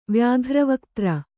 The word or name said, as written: ವ್ಯಾಘ್ರವಕ್ತ್ರ